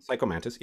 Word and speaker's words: Psycho Mantis: It's just a kid version of Psycho Mantis, where like Psycho Mantis? Am I saying his name right? – Yeah. – Psycho Mantis.